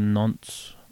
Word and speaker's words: Nantes: He was playing for Nantes or in France.